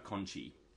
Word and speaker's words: Conchi: It's not Conti, it's Conchi. – I always say Conti. – But... It's spelt Conti, but listen to them, they're saying Conchi all the time.